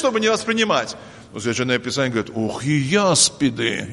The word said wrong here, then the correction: аспид: или аспиды, наверное, аспид, да? Правильно произносится, ядовитый.